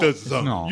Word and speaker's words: DAZN: DAZN. You're right. You would assume it's an acronym of some sort, but instead, it's spelled D-A-Z-N, but pronounced DAZN.